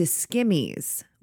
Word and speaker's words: Skimmyz: She changes the name of Yeezy to Skimmies with a Z. So it's spelled S-K-I-M-M-Y-Z.